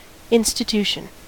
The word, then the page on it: institution: American English